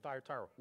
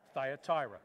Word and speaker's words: Thyatira: So this morning, we're going to look at the church of Thyatira. Okay, on three, say it with me. Thyatira. One, two, three. Thyatira.